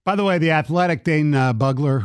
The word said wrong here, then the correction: Brugler: who Bugler, I don't know how to pronounce it. – Bugler. – What? Brugler. I said Bugler. – Brugler. Yes, you did.